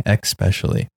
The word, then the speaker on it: especially: One word you've been mispronouncing is especially. You've been saying especially, but it's especially. Focus on the S.